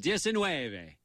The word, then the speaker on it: diecinueve: How do you say 19? Novechingo. Oh. Dos, uh, diecinueve.